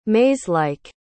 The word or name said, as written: maze-like